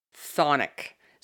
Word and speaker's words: chthonic: Or thonic. So there's the K. Some pronunciations are silent. Some have that K in there. But if you Google it, it's actually thonic, but it's spelled with a C at the beginning.